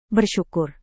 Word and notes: bersyukur: indonesian

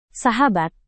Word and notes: sahabat: indonesian

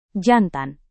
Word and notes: jantan: Indonesian

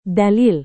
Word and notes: dalil: Indonesian

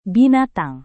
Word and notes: binatang: indonesian

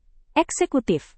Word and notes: eksekutif: indonesian

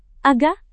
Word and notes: agak: indonesian

